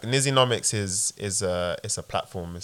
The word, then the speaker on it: Nizzynomics: Did I say it correctly? – Nizzynomics. – Nizzynomics. – Yeah.